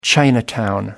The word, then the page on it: chinatown: American English